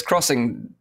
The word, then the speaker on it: Xing: Is it pronounced Xing, actually, or is it Xing? – Yes, crossing the land.